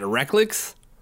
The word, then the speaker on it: Rechlicz: R-E-C-H-L-I-C-Z. – Oh boy. – How do you pronounce that? Reklicz? – Okay, yeah. Yep, that's how you pronounce it.